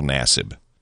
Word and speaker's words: Nassib: Carl Nassib, I guess, is the correct pronunciation of the young man's last name.